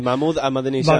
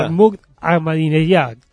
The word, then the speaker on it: Mahmoud Ahmadinejad: Bueno, pronunciaste bien el nombre del presidente. – Sí, Mahmoud Ahmadinejad. – Mahmoud Ahmadinejad, creo que se dice bien así.